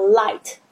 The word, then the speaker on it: light: N-I-G-H-T 它的 I-G-H 基本上都没有发音，所以你会看到很多的字，比如说像 light 灯光 L-I-G-H-T 那个 I-G-H 也是没有发音，比如说像是骑士 K-N-I-G-H-T 也是 N-I-G-H 没有发音，